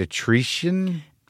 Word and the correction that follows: Eritrea: Eritrea? – Eritrea.